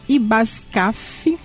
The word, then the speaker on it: IBASCAF: O Instituto de Benefícios e Assistências aos Servidores Municipais de Cabo Frio, IBASCAF, o F é mudo, está oferecendo 36 vagas através de concurso para os níveis fundamental, médio e superior.